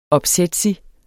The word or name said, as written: opsætsig